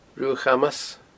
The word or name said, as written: Ruadh Chamas